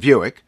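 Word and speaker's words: Vuic: So I called the author, Jason Viewick, as in rhymes with Buick.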